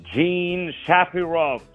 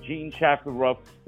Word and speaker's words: Jean Shafiroff: Her name is Jean Shafiroff. Jean Shafiroff. Let me spell it for you. Jean, J-E-A-N, Shafiroff, S-H-A-F-I-R-O-F-F.